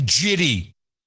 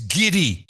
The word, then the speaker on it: giddy: Stop saying jitty. It's giddy.